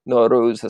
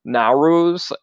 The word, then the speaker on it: Nowruz: I asked Aresh if I was pronouncing Nowruz right. And he was just very kind. And he was like, oh, no, you know, there's different ways of pronouncing it. But Nowruz is the proper way. I was saying Nowruz.